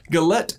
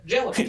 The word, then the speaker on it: Gellert: Gellert.